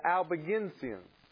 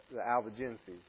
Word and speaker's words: Albigensians: And in those, you have, and I always say these names wrong, but I believe it is pronounced the Albigensian. I've always pronounced it the Albigenses.